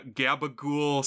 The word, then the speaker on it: capicola: But apparently it's something they say a lot on The Sopranos, and it's like the New Jersey way to pronounce capicola ham. – Oh, interesting. That's funny. – And there's like a gabagool Sopranos supercut of every time they say it, and it's a lot of times.